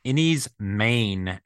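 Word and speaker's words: Enys Men: But it is, and I've been corrected by people online from the UK because in Welsh, it means Stone Island, but it's pronounced Innie's Main, even though it's spelled men, Main as in M-A-I-N.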